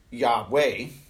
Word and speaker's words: Yahweh: And the Israelites would say, He is Yahweh, which is the actual pronunciation of the word behind the four capital letters, as near as we can get that pronunciation.